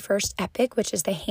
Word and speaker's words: Epoch: So that being said, I am going to be doing a podcast under the name of the school, which is phonetically Epoch, E-P-O-C-H, but we call it Epic because it sounds really cool.